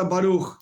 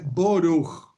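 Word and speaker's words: Baruch (ברוך): En hebreo sencillo, se en hebreo moderno se dice Baruch. El hebreo, como pronuncio yo, por ejemplo, es una pronunciación ashkenazí del estilo rusa, se dice boruch, no baruch, boruch. Hay otro tipo de pronunciación, húngara, por ejemplo, burij.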